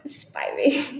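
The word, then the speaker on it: Spyri: Joanna Spry. – Spiry. – Oh, yeah. I always say it wrong. Spiry.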